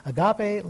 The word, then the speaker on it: agape: Agape, A-G-A-P-E, pronounced U-H dash capital J-G-A-H dash P-A-Y, agape.